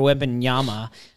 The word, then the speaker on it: Wembanyama: Um, and then you just think about to the future draft and Victor Wembanyama. I think I actually almost said that right. Yeah, that was good.